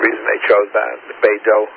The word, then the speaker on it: Beidou: BDS, Beidou, I think is the way they pronounce it, Beidou.